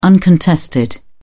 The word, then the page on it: uncontested: British English